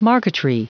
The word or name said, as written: marqueterie